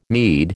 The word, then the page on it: Mede: American English